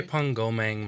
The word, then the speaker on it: Drepung Gomang: Is it called the Diprang Monastery? – Drepung Gomang Monastery. – Oh, you said it correctly.